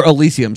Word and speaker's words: Elysium: he was in Asylum, or Elysium, sorry, I always say that wrong.